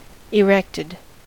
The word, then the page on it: erected: American English